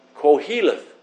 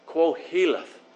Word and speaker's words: Qoheleth: And that word preacher in the Hebrew is Q-O-H-E-L, E-T-H, and it's pronounced in the phonetic, Koheleth. Koheleth. That's how it's pronounced phonetically.